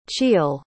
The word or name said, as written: chiel